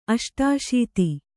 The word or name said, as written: ಅಷ್ಟಾಶೀತಿ